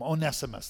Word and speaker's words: Onesimus: Onesimus. That's how I heard it in college, right? Some say Onesimus, but I don't think I like the long E.